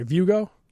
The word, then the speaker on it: Vugo: I hope I pronounce this right, Vugo? – That's correct. Just yes. Like Hugo Boss.